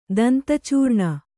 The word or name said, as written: ದಂತಚೂರ್ಣ